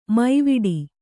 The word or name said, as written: ಮೈವಡೆ